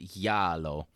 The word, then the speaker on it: giallo: a genre called giallo, which is yellow. But it's spelled, you're going to see it, G-I-A-L-L-O.